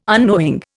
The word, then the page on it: unknowing: Indian